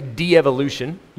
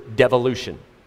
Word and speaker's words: devolution: So you can say de evolution, you can say it like that. I think the proper pronunciation for that word is devolution. Devolution, just the way it's spelled. Right? So I, am saying de evolution so that you understand we're going backwards. But the proper way to pronounce it is devolution.